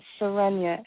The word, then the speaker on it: Serenyah: My name is, my name is Serenia, and I'm looking for any messages from the angels that you can provide. – So did you say your name again, Serenia? – Oh, Serenia, S-E-R-E-N-Y-A-H.